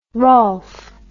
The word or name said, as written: rolph